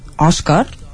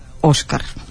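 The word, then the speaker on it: Oscar: i amb la pronúncia de la O oberta, és a dir, Òscar i no Òscar.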